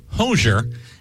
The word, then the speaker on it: Hozier: As we learned last week, he pronounces his name Hozier,